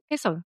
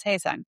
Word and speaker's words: Hae-sung: Did I said that wrong? – No, you said it right. Hae-sung. – Okay. Yeah. Yes. Yes. Hae-sung.